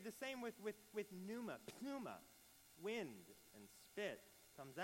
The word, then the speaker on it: pneuma: The word for Spirit in Greek is pneuma. And if you were to pronounce it incorrectly, it would be pneuma.